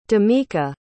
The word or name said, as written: dameka